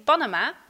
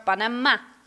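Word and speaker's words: Panamá: In het Spaans zegt ze niet Panama, maar Panama, met de klemtoon op de laatste A.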